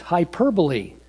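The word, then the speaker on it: hyperbole (ὑπερβολή): The word in the original language here is actually pronounced hyperbole.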